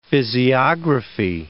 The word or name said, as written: physiography